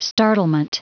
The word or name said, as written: startlement